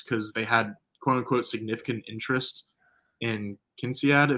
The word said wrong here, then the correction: Kincaid: if that's how you say his name, Don Kincaid, Kinsiad. I think. – Well, uh, Kincaid. I knew I was saying it wrong.